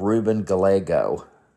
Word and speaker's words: Gallego: Ruben Gallego. And I think that's the way it's pronounced. His last name is spelled G-A-L-L-E-G-O.